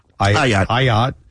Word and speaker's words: Ayotte: So, you know what's interesting is that New Hampshire, Kelly Ayotte, I never could say her name right. – Ayotte. Kelly Ayotte.